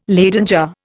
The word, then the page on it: Leyden jar: Indian